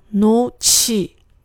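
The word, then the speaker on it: 놓지: 不要读成 “no 지”，而要读成 “no 치”。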